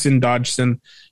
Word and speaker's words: Dodgson: Well, either way, it's spelled Dodgson. So it's Dodson.